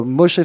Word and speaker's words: Moshe: Moshe, je le dis bien. – Moshe.